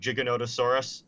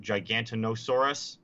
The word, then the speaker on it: Giganotosaurus: So it is funny watching like people try and read Giganotosaurus and pronounce it Gigantinosaurus.